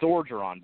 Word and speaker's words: Orgeron: So it's Orgeron, by the way, is how you pronounce his last name.